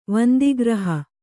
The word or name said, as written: ವಂದಿಗ್ರಹ